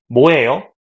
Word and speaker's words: 뭐예요: So a lot of Koreans, they pronounce it like Mo, which is your name, Monique Mo. – Mo. – But, Mo. – I mean, technically, Bo is right. 뭐예요, but it's too hard to pronounce. So these days, people just say 뭐예요, like that.